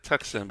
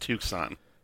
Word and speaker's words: Tucson: Yeah, it looks like Tucson, but it's pronounced Tucson.